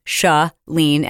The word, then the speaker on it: Shalene: You are required to know how to pronounce my name, Shalene, as in shut the door.